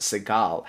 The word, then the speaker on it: Segall: Professor Segall, or pardon me, I believe it's pronounced Segall,